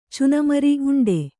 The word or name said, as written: ಉಂಡಿ